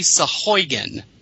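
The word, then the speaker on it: Sahuagin: Well, actually heard it's supposed to be Sahugan, like Sheboygan.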